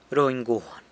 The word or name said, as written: runy guan